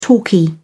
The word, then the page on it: talkie: British English